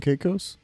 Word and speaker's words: Caicos: But, yeah, we landed in, or we ported in Grand Turk, which is part of the Quirquos Islands. – What's that? – Well, how do you say it? – Turk and Caicos? – Turk and Caicos Islands, yeah.